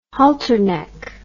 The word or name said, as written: halterneck